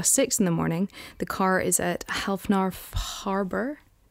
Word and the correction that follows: Hafnarfjörður: I can show you that, because I actually do have it written down, and that's probably why I'm pronouncing it so badly. This? – Hafnarfjörður.